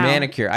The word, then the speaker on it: manicure: And then what I wanted to ask you is don't when you get like a medicure or a pedicure, don't they sometimes wear them? I feel like I've seen. – I'm not a medicure. – Medicure. – Wow. – Manicure. I said it wrong, actually.